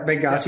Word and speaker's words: Benghazi: Benghazi, yeah.